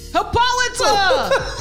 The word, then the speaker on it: Hippolyta: And so a few things, one of the things that Hippolyta, that how you say it? – Who? – Hippolyta, her mom? – Hippolyta!